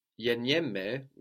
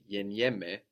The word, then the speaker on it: γεννιέμαι: Γεννιέμαι, which is the γάμα e double n I e, which gives us this ye sound, mai, γεννιέμαι, I am born, is actually related to genetics.